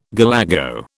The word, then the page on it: galago: British English